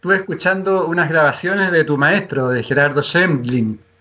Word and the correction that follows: Schmedlin: Schmedlin. – ¿Cómo se pronuncia? Schmedlin.